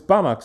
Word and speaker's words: Banach: But it doesn't matter because the math pronunciation is Banach spaces.